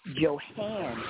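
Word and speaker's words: Johanne: And my, I think I'm saying that right, is Joanne, J-O-H-A-N-N-E, S-U-N-F-L-O-W-E-R, Johann Sunflower. I have an H in my name, Johann Sunflower.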